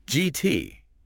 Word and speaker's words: Gitea: Gitea is pronounced toti. – Okay. – So think GT with a hard G sound, like in gift.